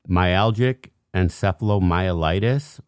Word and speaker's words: myalgic encephalomyelitis: myalgic encephalomyelitis or chronic fatigue syndrome? – Yep.